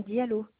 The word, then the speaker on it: Diallo: Alors, je suis Madame Diallo, DIA2LO.